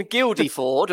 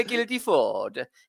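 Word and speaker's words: Guildford: In Guildeford, or however you pronounce it, which was another highlight of the podcast, in a Guilty Ford. It's like your Italian heritage was coming out and you just didn't know how to say it properly.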